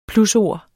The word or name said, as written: plusord